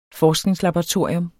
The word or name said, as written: forskningslaboratorium